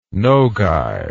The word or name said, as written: nogai